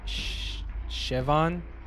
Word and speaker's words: Siobhan: I was just corrected. The girl's name is pronounced Siobhan. – Siobhan.